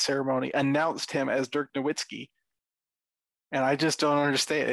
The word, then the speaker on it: Nowitzki: the media, professionals, mispronounce Dirk Nowitzki's name at such a abundant level that they call him Dirk Nowitzki, which is Americanized.